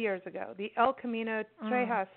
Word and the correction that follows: El Camino Real de los Tejas: The El Camino Real de los Tejas, and it goes from Laredo, Texas.